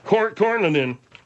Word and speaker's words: Cornyn: Cornyn.